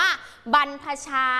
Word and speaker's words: บรรพชา: คำนี้จะออกเสียงว่าคำแรกนะคะจะออกเสียงว่าบรรพชา